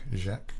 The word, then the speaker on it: Jacques: But that's pronounced Jacques, as in Jacques Brel or Jacques Cousteau.